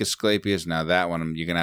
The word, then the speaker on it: Asclepius: Asclepius. – Yeah, you said it right, buddy.